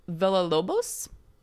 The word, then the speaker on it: Villalobos: Okay, you said that exactly the way that Butch's character in Pulp Fiction says the name of the taxi driver, Villalobos. – Am I saying it wrong? – I don't think so.